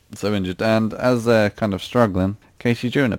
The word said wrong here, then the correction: Casey Jones: appears. Casey Jones? I said it wrong. What's Casey Jones? – It's Casey Jones.